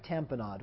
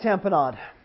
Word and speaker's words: tamponade: Oh, yeah, and here's a life-threatening acute one is cardiac tamponade. What's cardiac tamponade? Like tampon? Aid is how you spell it. A-D-E. Tampon, A-D-E.